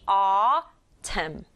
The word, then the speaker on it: autumn: A, U가 만나서 어 하는 소리 나요. 그리고 T에다가 소리 나는 U, 그리고 소리 나는 M. N은 소리가 안 나네요. 그래서 발음이 autumn.